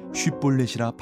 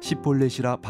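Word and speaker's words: shibboleth: 그에게 이르기를 쉿볼렛이라 발음하라 하여 에브라임 사람이 그렇게 바로 말하지 못하고 시폴렛이라 발음하면 길라 사람이 곧 그를 잡아서 요단강 나루턱에서 죽였더라.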